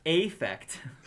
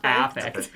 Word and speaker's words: affect: And the A's are anhedonia, flat affect. How do you say that? I always say it wrong. – Affect. – Affect.